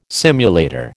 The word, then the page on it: simulator: American English